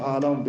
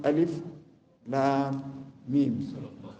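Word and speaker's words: Alif Lam Mim (الم): If it was not the Prophet that was teaching the Sahabat, we wouldn't have known how to pronounce these three letters. We would have thought, is it Alam? Because you just see Alif Lam Mim. But the Prophet said is pronounced Alif Lam Mim.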